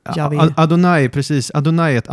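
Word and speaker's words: Jahve: Just det, Javé. Javé, mm. man tror det uttalades så.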